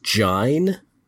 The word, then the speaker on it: join: Join is pronounced jine. – Huh. – That yeah. – O-I. And so you see it in rhymes a lot and you're like, what is Dryden doing there? – Yeah.